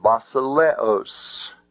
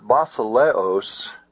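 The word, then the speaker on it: basileos (βασιλέως): I want to look at the word basileos in your examples there in your syllabus. Word basileos has 1, 2, 3, 4 syllables. Ba-si-le-os.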